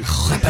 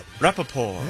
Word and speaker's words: Rapoport: Oh, silent T, huh? – Yeah, thank you. – Rapoport. I did not know that. – Rapoport.